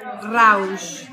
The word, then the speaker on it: Rausch: Yo estoy aquí platicando con el chef Jorge Rausch.